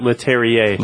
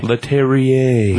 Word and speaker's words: Leterrier: Leterrier. – Leterrier.